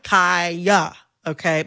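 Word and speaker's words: Khia: I am going to, in this conversation, pronounce Kaya, the rapper, with the long I. Kaya, okay? My neck, my back. – for those who need a refresher. Kaya, okay? Mm-hmm. The Kia Asylum is a mispronunciation of her name.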